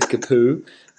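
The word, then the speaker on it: Capoue: Now, Watford had their first goal scorer by Capu, who I just found out his name is Capu. I thought it was Capue. I was like, I would be pronouncing it Capue, but last night watching the replay, the commentator just go, Capu!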